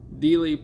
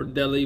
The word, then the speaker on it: Dealey: Dealey Plaza, or Delhi Plaza. I've heard it pronounced both, in Dallas, Texas.